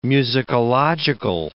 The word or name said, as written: musicological